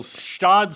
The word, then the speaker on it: Gstaad: Yeah, and believe it or not, Neil, Stod starts with a G. There's a silent G. – What? – Yeah, you, I believe you know what that the spelling, reminds me of? Joel, if you check, is G-S-T-A-A-D, if I'm not mistaken.